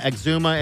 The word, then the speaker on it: Exuma: They said Exuma, right? Exuma? How do you say that? – Yeah, Exuma.